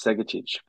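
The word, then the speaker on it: Segečić: Help me out with the pronunciation. – No. You just smashed Segacic for like the first time ever.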